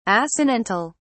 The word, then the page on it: assonantal: British English